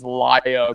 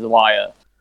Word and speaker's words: Lya: So just about everyone I know has been pronouncing it Lya Skye. – No, it is Lya, according to Nick. – According to Nick. – The Y is a hard voweled Y.